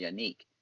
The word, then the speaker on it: Yannick: And I found out that that's how it's pronounced, Yannick, according to my good sources at Pro Football Focus.